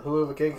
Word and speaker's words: Hell of a Kick: Yeah, the Huluva kick. I know it's spelled hell of a, but whatever.